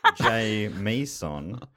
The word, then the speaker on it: Jamison: In brackets, he's written pronounced J. Meeson. Matt always gets it bloody wrong.